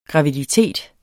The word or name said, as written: graviditet